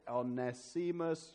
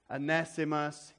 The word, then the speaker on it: Onesimus: Verse 10, a guy called Onesimus. I don't know how to pronounce it. Onesimus. You did it very well, Richard. I was very impressed with that. Onesimus.